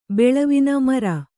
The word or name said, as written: ಮೆರೆ